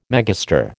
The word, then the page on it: megastar: American English